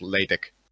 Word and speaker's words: LaTeX: First of all, I think it's pronounced LaTeX, not LaTeX.